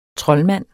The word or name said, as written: TROLDMANDEN